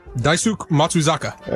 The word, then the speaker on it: Daisuke Matsuzaka: How do you pronounce his real name? Try it. – Daisuke Matsuzaka. – I nailed it.